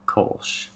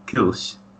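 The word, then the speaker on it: Kölsch: But the American style, the American pronunciation would be Kolsch. But if you were German, you'd probably say something like Kolsch.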